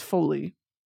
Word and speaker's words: Foley: How to pronounce Foley? – The following pronunciation is brought to you by Pronounce... – Don't care. – Foley. – Foley. Lee. Rhymes with C.